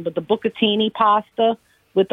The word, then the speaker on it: bucatini: Bucatini, you said it right.